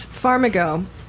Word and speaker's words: Farmigo: So, to talk about Farmigo. How do you pronounce that? It's Farm Plus Amigo, yes.